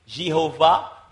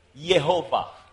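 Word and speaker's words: Jehovah: Donc, et ils préfèrent pas qu'on dise Jéhovah, ils préfèrent dire Yehovah.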